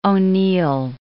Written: O'Neill

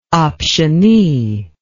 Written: optionee

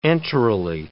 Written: enterally

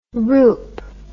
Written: roop

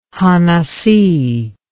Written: hanasi

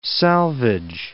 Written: salvage

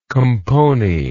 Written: compony

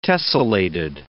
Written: tessellated

